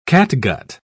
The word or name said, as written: catgut